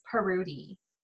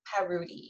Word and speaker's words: Parrouty: But if you want to, it's pronounced Parrouty. – Parrouty. – Parrouty, yeah. It's French, but it's a really weird, like, obscure French name.